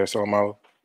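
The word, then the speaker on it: Seumalo: So now you have Brandon Brooks, you have Lane Johnson, and you have how do you say his name? Isaac Salamano? – Yeah, Salamano. – Salamano.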